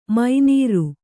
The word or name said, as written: ಮೀನಾರ್